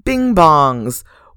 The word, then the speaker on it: bing bongs: They pull into a gas station and Sam goes inside and Dean asks for his usual, rhymes with sing songs. You'd think it would be ding dongs, but no, it's something called bing bongs, which I would like to point out are cakes they're little snack cakes.